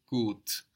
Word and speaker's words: gut: The word for good is gut, GUT. And that U gives us a long O sound, like in food. Gut, gut. My neighbors are good.